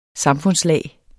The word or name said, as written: SAMFUNDSLAG